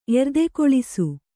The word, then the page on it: ಎರ್ದೆಕೊಳಿಸು: Kannada